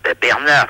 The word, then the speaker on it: Bernart: Bernard, si, c'est mon prénom, mais ça s'écrit pas comme vous me le prononcez. Ça s'écrit B-E-R-N-A-R-T.